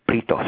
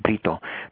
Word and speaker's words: Brito: And, you know, so my last name is actually pronounced Brito. So you got to roll the R, Brito.